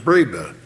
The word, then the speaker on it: Breba: The modern name is, Breva, B-R-E-B-A. It looks like it should be Breva, but it's pronounced Breva.